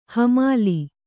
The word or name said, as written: ಹೆಮ್ಮಲೆ